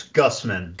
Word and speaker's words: Gusman: Jason, did I pronounce that right, by the way? – No. Okay. Sorry. – Okay. Well, we no pronounced it correct now. – Right. It's Guzman.